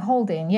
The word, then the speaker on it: Haldane: Haldane, I think. Is that how you pronounce it, Mum? – I think Haldane, yes. – Haldane, okay.